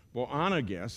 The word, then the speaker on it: Boanerges: James, the son of Zebedee, and John, the brother of James, to whom he gave the name Boanerges, that's how that's pronounced, believe it or not, that is Sons of Thunder.